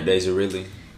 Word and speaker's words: Daisy Ridley: I really, really, really liked Daisy Ridley. Is that how you say her name? – Yeah, Daisy Ridley.